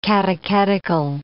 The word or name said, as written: catechetical